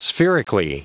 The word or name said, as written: spherically